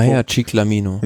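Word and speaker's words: Maglia ciclamino: Maya Ciclamino.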